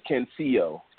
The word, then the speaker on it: Cancio: I don't know why I keep on saying his name wrong. Yeah, Cancio.